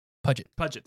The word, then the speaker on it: Puget: So next up is, what does Jade Puget, is that how you pronounce it, Jade Puget, or is it Puget? – Puget, thank you.